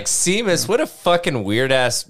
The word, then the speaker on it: Seamus: Anyway, and they pronounced it Seamus, and I was like, wow, I'm an idiot.